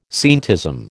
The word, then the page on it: scientism: American English